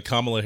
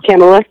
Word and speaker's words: Kamala: So, are you familiar with Kamala Harris? – Yes, Kamala. – Oh, is it Kamala? Am I saying it wrong? – Yes. Yes, just Kamala.